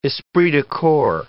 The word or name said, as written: esprit de corps